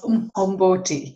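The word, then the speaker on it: umqombothi: And it's actually pronounced Umhomboti. So there are three clicks it's in the Xhosa language.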